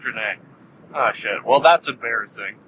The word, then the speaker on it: Drennai: I only ask one thing, and that is that you stop these people from invading the Drennai." Not the Drennai. I think I've been saying the name wrong the whole time. – No, you in fact have been saying it correctly the whole time.